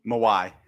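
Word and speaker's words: Mawae: Coach, I don't know how to say it. Maui. – Mawai. – Mawai. – Yeah, Kevin Mawai.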